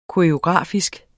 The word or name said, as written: koreografisk